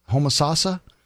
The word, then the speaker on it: Homosassa: The other day was fishing with some teammates in, I believe it's pronounced Homosassa, Florida. – Homosassa? – Homosassa.